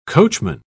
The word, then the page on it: coachman: British English